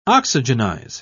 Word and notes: oxygenize: British English

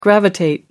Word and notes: gravitate: British English

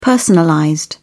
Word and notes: personalized: British English

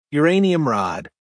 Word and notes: uranium rod: American English

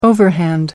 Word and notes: overhand: American English

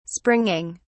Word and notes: springing: British English

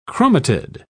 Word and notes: chromatid: British English

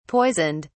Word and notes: poisoned: British English

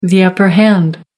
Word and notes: upper hand: American English

